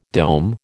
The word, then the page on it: dome: American English